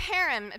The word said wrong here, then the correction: Hiram: Hiram, let's say Hiram, of Tyre sent messengers to David along with cedar timber and carpenters and stonemasons, and they built David a palace.